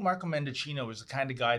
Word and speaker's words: Mendicino: You know, Tamara, I have to say there is a similarity with Marco Mendocino, rhymes with Pinocchio, by the way, and Paul Bernardo.